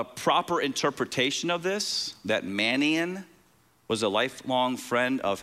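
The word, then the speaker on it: Manaen: This next guy's name, I kept listening to it over and over so I could pronounce it right. It's Manaane. Okay, that's how you pronounce it.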